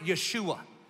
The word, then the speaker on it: Yeshua: They would have pronounced it Yeshua, but it means Savior.